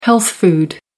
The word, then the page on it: health food: American English